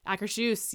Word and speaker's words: Akershus: I can't remember how you say it. Akershus? – Akershus. – Akershus, yes.